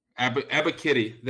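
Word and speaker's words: Ebiketie: but Ebikidi, right? I believe that's Arnold. Arnold Ebikidi. – Ebikidi. There we go.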